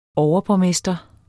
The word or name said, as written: overborgmester